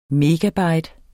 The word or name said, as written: megabyte